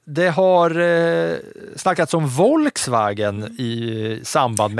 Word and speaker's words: Volkswagen: Tänk på uttalet här va? Ja, förlåt. Jag är bara bra på, itali jag, jag, på italienskt uttal. Det här är ju tyskt, det får ju du ta. – Ja, Volkswagen. – Ah, det är Volkswagen.